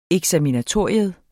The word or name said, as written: eksaminatorium